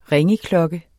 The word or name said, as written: ringeklokke